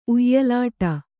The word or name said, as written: ಉಯ್ಯಲಾಟ